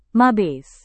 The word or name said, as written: mabes